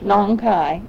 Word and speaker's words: Nong Khai: Nong Khai in Thailand. – And you know how to spell that? – N-O-N-G.